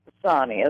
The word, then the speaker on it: Sonni: My name is pronounced Sonny, S-O-N-N-I.